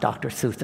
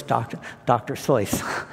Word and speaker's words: Seuss: Well, it's spelled the same, R-E-U. And the other one we mispronounced, everybody knows Dr. Seuss. But it's not Dr. Seuss. It's like Jerry Royce, Dr. Seuss, John Neumann, and so forth.